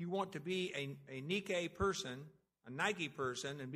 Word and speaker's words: Nike: Of course, you pronounce it in Greek, you pronounce it Nike.